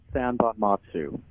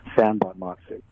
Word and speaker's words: Sanbonmatsu: It's pronounced Sanbonmatsu, which is actually how it's spelled, Sanbonmatsu. It took me to third grade to learn how to spell that name, Sanbonmatsu.